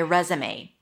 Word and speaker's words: resume: Re, as in red, so round your lips for the R. Z, it's a Z sound and a schwa. Rez, and it's confusing because it's spelled with an S, I know, but it's pronounced as a Z. Resume. May. A as in day.